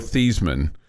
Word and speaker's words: Theismann: His name was Joe Theismann at Notre Dame, and he changed his name to rhyme with Heisman because mm-hmm.